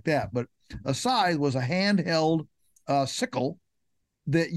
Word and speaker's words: scythe: So a scythe was simply a blade that you swung, you know, with your, if you're wondering how to spell it, it looks like skithy. All right. So it's S-C-Y-T-H-E, pronounced scythe.